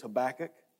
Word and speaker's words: Habakkuk: I would tell you, first of all, I'm going to tell you, that's the way I pronounce his name. You might have a different way of pronouncing it, and that's okay. I think this is as close as I'm going to get. It's Habakkuk.